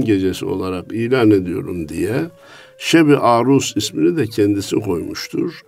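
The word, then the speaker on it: Şeb-i Arus: Bir kısmı Şeb-i Aruz diye onu telaffuz eder. Yok, aruz vezniyle alakası yok. Sonu S. Şeb-i Aruz.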